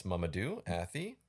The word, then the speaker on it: Mamadou Athie: Okay, you did a little research on how to pronounce her name, so I'll let you go for it. Who plays Dan, the lead character Dan? – That's Mamadou Athie.